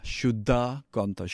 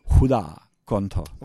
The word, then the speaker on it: Huda Kantha: In Sanskrit, other bases, other places in India, they would pronounce it Shuddha. Shuddha Kantha. Shuddha means pure, and Kantha means voice. But in Assam language, they've got this ha. They don't say sha, they say ha. Huda Kantha.